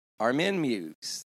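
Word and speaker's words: Armin Meiwes: Armin Meuse, the German cannibal who posted an ad to eat someone's penis, and that's what happened.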